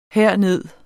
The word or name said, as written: herned